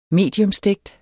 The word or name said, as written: mediumstegt